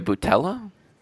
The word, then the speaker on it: Boutella: You got Sofia Boutella. I think that's how you pronounce her last name. We all know I'm bad at pronouncing sure. names. – Sounds right.